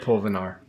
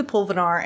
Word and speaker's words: pulvinar: So I always get excited when somebody brings up the pulvinar.